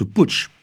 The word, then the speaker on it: Puig: On commence par les plus connus, Pêche ou Puèche, la colline, un nom encore plus courant en catalan où ça s'écrit Puig et se prononce Puch.